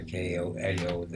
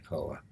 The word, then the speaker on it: KOA: they had a KOAO there. I call it Koa because Eloise had a bear, elephant named Koa. Uh-huh. But I understand that you don't pronounce it Koa, it's KOA. Campgrounds of America or something.